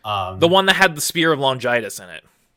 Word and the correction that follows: Longinus: In the older one, it was pronounced Longinus.